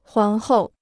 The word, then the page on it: 皇后: huáng hòu